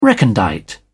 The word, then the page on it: recondite: British English